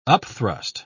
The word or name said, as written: upthrust